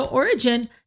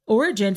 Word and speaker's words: Origen: So Origen, his name is spelled O-R-I-G-E-N, was born in Alexandria, and he was born to a very devout Christian family.